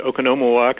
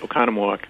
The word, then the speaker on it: Oconomowoc: Well, it depends. I had an uncle who used to pronounce it Oconomowoc, and others pronounce it Oconomowoc.